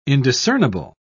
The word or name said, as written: indiscernible